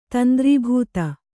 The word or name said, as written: ತಂದ್ರೀಭೂತ